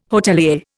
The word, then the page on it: hotelier: Indian